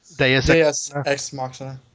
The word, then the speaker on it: Deus ex machina: Deus ex machina. – Deus ex machina.